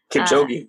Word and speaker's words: Kipchoge: There's a really good one with, I'm probably going to say his name wrong, but it's Eliud Kipchoge. – Kipchoge, yes.